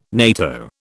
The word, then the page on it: NATO: British English